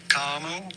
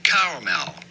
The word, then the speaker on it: caramel: There's going to be two different ways depending on your personal preference. – Oh. – Either caramel, caramel, or caramel, caramel.